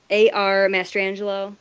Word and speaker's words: ARMastrangelo: You can find me on Twitter at @ArMastrangelo.